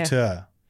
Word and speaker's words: auteur: So she can create herself as a bit of a Netflix aorta. – Mm-hmm. – Aorta is the thing in your heart. Yeah. Auteur. I can't say it properly. A-U-T-E-U-R.